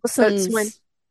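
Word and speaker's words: boatswain: And one of the things I got the other day was something about becoming a, how do you say it? Boatswan? Boatswan? – Bosun's Yeah. – bosun.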